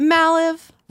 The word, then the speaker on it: Malive: Malive.